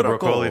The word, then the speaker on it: Broccoli: The Broccoli family, who does all the James Bond movies.